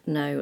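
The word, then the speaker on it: know: Knit, knob, kno, knuckle. – I think you mean knee, knit, knob, no, and knuckle. – Well, might do. – Silent L in calf, C-A-L-F, talk, T-A-L-K, and should, S-H-O-U-L-D.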